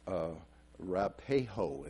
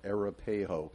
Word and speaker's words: Arapahoe: And I guess it's phonetically pronounced Arapahoe is what she was saying, Arapahoe.